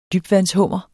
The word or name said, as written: dybvandshummer